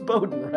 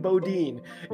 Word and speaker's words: Bowden: Mr. Bowden Waka, and by the way, if you or any of your teammates are listening, I'm pretty sure it's pronounced Bowden, right? Like they keep saying Bodine.